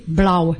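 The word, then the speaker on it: blá: Bleu, ça s'écrit B-L-A avec un accent aigu. Hein? Le A accent aigu se prononce A-U. Hein? Donc ça fait blau. blau.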